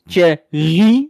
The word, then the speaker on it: Thierry: Nou, zegt hij, het is Thierry met de klemtoon op Rie en dan Baudet.